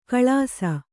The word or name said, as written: ಕಳಾಸ